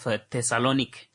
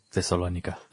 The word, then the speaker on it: Thessaloniki: By the name of Ioannis Peters, from Aristotle University of Thessaloniki in Greece. – Thessalonica. – Thessaloniki. I don't know, Don't worry, Yama. – Thessaloniki? I think that's the Greek pronunciation.